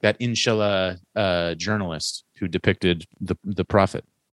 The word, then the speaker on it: inshallah: So according to the Oxford Dictionary, it is pronounced inshallah.